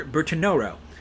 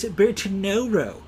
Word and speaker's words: Bertinoro: Uh, I may not be pronouncing that correctly, near Bertinoro, uh, which I believe is how you're supposed to pronounce Bartnura. Okay? So, if you want to be a jerk, and the next time somebody passes you a glass of Bartnura, you can say, no, no, no, it's Bertinoro.